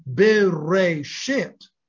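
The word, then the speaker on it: bereshit (בראשית): That is to say, that the fact that the first word in the Torah, spelled bet, resh, aleph, shin, yod, tav, is pronounced bereshit.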